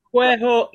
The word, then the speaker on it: Quehok: Quehok, is that how you pronounce the album? – Quehok is exactly right. Yes, a lot of people sort of go, oh, Quehok or whatever.